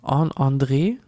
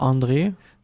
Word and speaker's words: André: Ich glaube, warte mal, André spricht man so aus, ne? – Also ja. im Deutschen natürlich eher so André, aber André. Ja.